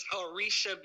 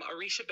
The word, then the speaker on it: Orixa: Well, I um, am Orixa. My name is Orixa Bowers. Yes. I am, I am affectionately known as Dr. O. Uh, by many who have trouble pronouncing Orixa, but I'm Orixa Bowers.